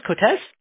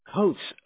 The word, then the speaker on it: Coates: C-O-A-T-E-S. – And is that pronounced Cotes? – No, Coates. – Coats. Okay, gotcha, gotcha.